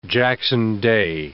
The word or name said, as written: Jackson Day